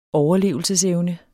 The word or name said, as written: overlevelsesevne